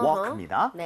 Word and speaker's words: walk: 아, 요거는 묵음이에요. Uh-huh. walk입니다.